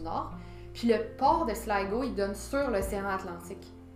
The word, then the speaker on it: Sligo: Je, pense qu'en irlandais, ça se dit Sligo. Euh, ça s'écrit S-L-I-G-O.